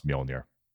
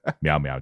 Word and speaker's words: Mjolnir: She can't pronounce Mjolnir. It's a pet peeve of mine. Meow, meow, Jay. – Meow, meow. – It has one M, and she called it Meow Meow.